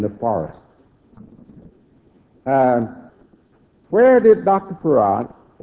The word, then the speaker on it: Fard: It's spelled F-A-R-D. But it's pronounced F-A-R-A-D, Farag.